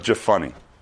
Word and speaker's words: Jephunneh: First off, his name is Caleb the son of Jephunneh. I looked it up. It's true. That's how it's pronounced.